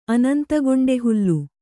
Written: ಅನಂತಗೊಂಡೆಹುಲ್ಲು

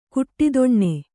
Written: ಘಟ್ಟಿತನ

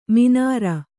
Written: ಮೀನಾರ್

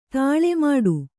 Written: ತಾಳೆ ಮಾಡು

